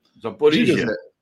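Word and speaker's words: Zaporizhia (Запоріжжя): É a Rússia reforçando a segurança da central de Zaporozhia, que chama Zaporozhia. – Zaporizhia. – É, como, como que se pronuncia? Zaporizhia. Zaporizhia, então diga lá.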